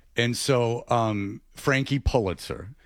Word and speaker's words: Pulitzer: No, he was like Pullman, like Bill Pullman. This is Pulitzer. Pull. Is Pull. There's Paul. – Pulitzer.